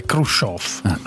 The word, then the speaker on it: Khrushchev: Comunque è Khrushchev, adesso c'è Khrushchev. Khrushchev. – Sof con la E o la O? – Con la IO e la E con i due punti sopra, in russo si legge IO, e qui fatti servire.